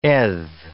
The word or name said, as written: eth